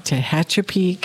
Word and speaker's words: Tehachapi: I never pronounced that town's name correctly. – Tehachapi? – Tehachapi, California. Yeah. – And I don't know whether I'm pronouncing it right. – That sounds right.